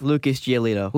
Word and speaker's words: Giolito: It rhymes with gelato. – Yeah. – Sort of. – Lucas Giolito.